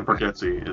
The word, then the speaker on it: Bargatze: But now I gotta check out Nate Bargatze. Is that how you pronounce it? – Yes, Nate Bargatze is fucking hysterical.